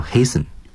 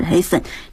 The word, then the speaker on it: hasten: 其实类似于 fasten 这样的单词还有 hasten. H-A-S-T-E-N.所以有的时候大家注意看这个单词啊，其实不要真的就是拿过来就猜啊，该查词典还是要查词典。 – More haste, less speed. 那个 hasten. 它的动词是 hasten. 这个 T 也是不发音的，和我们的这个 fasten 是一样的。